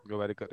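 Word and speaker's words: Gowariker: Gowarikar, yeah.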